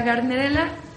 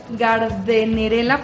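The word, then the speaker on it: Gardnerella: Ahora, en cuanto a la gardenerela, se está bien pronunciarla de las dos maneras. Gardenerela, porque lleva una D y luego seguida de una N, o gardenerela.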